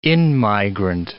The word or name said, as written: in-migrant